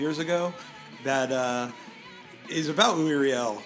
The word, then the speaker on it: Louis Riel: So to start off, by the way, I feel very good that it is pronounced Louis Riel.